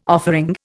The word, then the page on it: offering: Indian